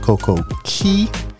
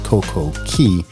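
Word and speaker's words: Coco Cay: You know, for some people may say Coco K, but how they pronounce it is Coco Key. I don't see how you get key out of C-A-Y, but hey, I'm going to go with it. It's Coco Key.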